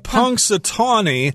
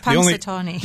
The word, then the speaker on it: Punxsutawney: Punxsutawney. – Punxsutawney.